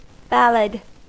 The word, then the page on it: ballad: American English